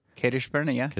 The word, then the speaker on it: Kadesh Barnea: Did I say that right? – Kadesh Barnea. – Barnea.